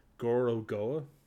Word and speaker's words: Gorogoa: is Goro Goa. So it's spelled G-O-R-O-G-O-A.